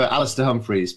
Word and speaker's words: Alastair Humphreys: You did butcher it, but I'll let you off. I go for Alastair Humphreys, but anything in an American accent sounds cool, so I'm easy with whatever it comes out of your mouth is fine.